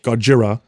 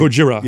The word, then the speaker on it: Gojira: Japan had Godzilla, which actually was called Gojira. but Americans pronounce it Godzilla. So you had Godzilla in Japan. – Is it really Gojira? – Like, Gojira yeah. – is the original.